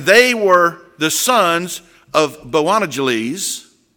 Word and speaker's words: Boanerges: It's called the son of, and I think it's pronounced, it's almost like Bojangles, but it's Boanerges. All right? So just think of Bojangles chicken.